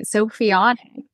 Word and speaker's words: Sophionic: Sophionic. Did I say it right? – Yes.